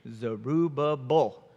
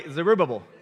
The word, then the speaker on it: Zerubbabel: Zerubbabel. Say it with me, everybody. Zerubbabel.